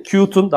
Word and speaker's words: Qt: KDE daha çok Qt'un daha doğrusu Qt diye okunuyor. Qt'un bir çalışma ortamı gibi bir şey yani.